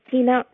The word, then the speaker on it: Teena: Hello, my name is Tina Ruark Goro. Tina is my first name and is spelled T-E-E-N-A, thanks to my innovative parents who replaced the I in the usual spelling of Tina with two E's.